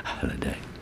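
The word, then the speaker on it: Halladay: I'm an awkward sod because my Halliday is spelt with all A's and everyone still wants to call me Halliday, but I'm a H-A-L-L-A-D-A-Y, Halliday.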